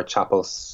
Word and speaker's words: Chapple: You can find me on Twitter, Ash Graham _Chapelle, Chapple. It's not French, and it's not spelled with an E-L.